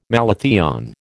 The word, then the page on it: malathion: American English